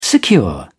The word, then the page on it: secure: British English